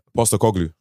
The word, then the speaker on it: Postecoglou: Postecoglou.